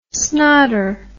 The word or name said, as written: snotter